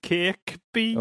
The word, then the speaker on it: Kirkby: Kirkby. – Oh, yeah. – That's boy. – You don't pronounce the second K, mate.